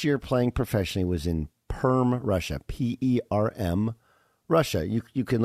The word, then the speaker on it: Perm: Perm, it's pronounced Perm, Russia.